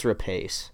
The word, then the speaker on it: Rapace: Noomi Rapace. Rapace? I don't I know how to say her name. I think it's Rapace.